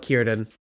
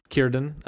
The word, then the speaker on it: Círdan: And Gil-galad's going to entrust Narya with his lieutenant Cerdan, or it's a Cerdan. I don't know how to pronounce that. I'm not a linguist. – I've heard people call it Círdan. – Círdan, yep.